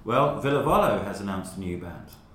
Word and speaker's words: Ville Valo: Ville Valo.